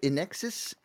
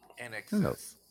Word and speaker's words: INXS: That was how do you say, is it Inexus? In INXS? How do you say that? – Inexus.